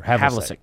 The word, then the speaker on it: Havlicek: Havlicek, I feel like that's how you say it. – I was going to say faster is better. – Havlicek.